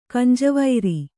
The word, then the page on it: ಕಂಜವೈರಿ: Kannada